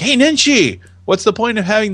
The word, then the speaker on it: Nenshi: Okay, so one person at replies, how do you pronounce this man's name? Nahid? – I don't know, but Nenshi? I'd say Nahid Nenshi.